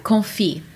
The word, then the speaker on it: confit: Yeah, confit. – Confit. – Yeah, so the T is actually silent.